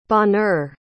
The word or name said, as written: bonheur